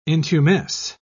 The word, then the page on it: intumesce: British English